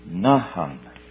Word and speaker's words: naham: Number two is N-A-H-A-M and is pronounced Nahum.